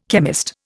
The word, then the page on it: chemist: Indian